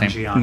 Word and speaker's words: Nanjiani: I forgot his last Nanjiani.